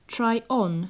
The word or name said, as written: try on